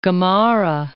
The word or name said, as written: Gamarra